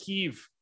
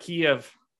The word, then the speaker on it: Kyiv: Yeah, I pronounce it Kyiv, not Kiev. I'm Ukrainian.